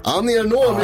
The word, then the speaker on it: Annie Ernaux: Annie Ernaux, mycket riktigt.